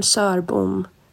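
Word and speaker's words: Sörbom: But again, my Swedish coach said it's Sarabom. Now I have permission to mangle it. Now that I tried once, I'm going to mangle it. – The proper pronunciations are Jarskog and Sarbom. Jarskog and Sarbom. I don't know how many times we have to go over this.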